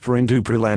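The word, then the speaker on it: perindopril: Pirindopril, pronounced Pirindupril, and Indapamide, pronounced Indapamide.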